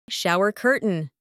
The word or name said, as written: shower curtain